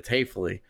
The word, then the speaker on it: Haefele: He said, "Hey, can I get a quick pronunciation for your name for my producer?" And this is like super normal, right? It happens almost every time. And I, yeah, sure, it's Hafley.